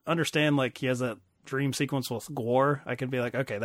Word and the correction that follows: Gwar: G-W-A-R? – I just love your pronunciation of it. It's great. – How do you pronounce it? It's not even like a real Gwar. – Word. – Gwar. – Gwar? – Yes.